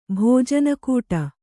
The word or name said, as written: ಭೋಜನಕೂಟ